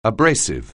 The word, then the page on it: abrasive: anglais